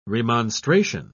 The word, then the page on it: remonstration: British English